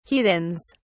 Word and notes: heathens: British English

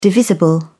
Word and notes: divisible: British English

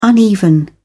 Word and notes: uneven: British English

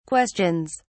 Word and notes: questions: British English

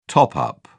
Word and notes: top-up: British English

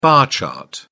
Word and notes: bar chart: British English

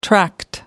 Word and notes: tract: American English

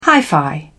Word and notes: hi-fi: British English

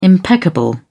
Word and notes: impeccable: British English

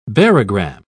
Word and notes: barogram: British English

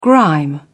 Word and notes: grime: British English

